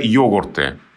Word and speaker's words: йогурт: И вот это вот уже йогурты с ударением на «о».